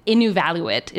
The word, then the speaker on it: Inuvialuit: So you represented Inuvialuit. Is that how you say it? – Can you say it? Yeah.